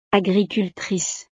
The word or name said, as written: agricultrice